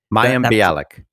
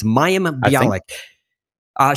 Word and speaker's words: Mayim Bialik: Mayim Bialik. – Mayim. Okay, it's Mayim Bialik.